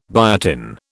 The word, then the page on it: biotin: British English